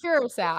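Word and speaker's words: Curaçao: Did I say that right? – Siracao or Curacao? – I don't know. Curacao. I think it's Curacao with that U.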